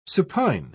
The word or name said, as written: supine